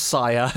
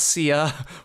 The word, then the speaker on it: Sia: Okay, so before people get on our asses on Twitter, it is pronounced Saya. It's spelled S-I-A. It's not Sia. We have clarified this, and we ourselves have been saying this wrong for a long, long time.